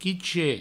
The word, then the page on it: quichet: provençal